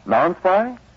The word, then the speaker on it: Nonspi: How do laboratories pronounce N-O-N-S-P-I? – Non-spy?